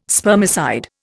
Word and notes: spermicide: Indian

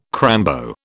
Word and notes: crambo: British English

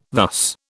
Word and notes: thus: British English

